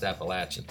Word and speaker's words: Appalachian: I think it's Appalachian. It's actually my friend Rob's son goes to Appalachian State, and he said it's pronounced Appalachian.